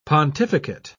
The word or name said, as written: pontificate